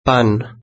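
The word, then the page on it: pan: Spanish (Spain)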